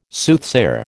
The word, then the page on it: Soothsayer: American English